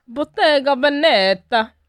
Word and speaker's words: Bottega Veneta: Bottega Veneta.